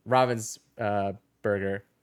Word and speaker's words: Ravensburger: I was like, oh, so that's how it's pronounced? And he's like, yeah, he's like, it's fine. Everybody pronounces it wrong, but this is the, you know, where it was founded and blah, blah, blah. And so, but yes, it is Ravensburger.